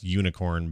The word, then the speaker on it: Unikrn: It's also pronounced, by the way, they say it's pronounced unicorn, but come on.